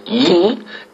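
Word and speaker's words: Yi (이): Alors, pour préciser quelques détails, en Corée du Sud, le nom de famille Lee, qui est souvent écrit L2E, se prononce Yi, et en Corée du Nord, il se prononce Li.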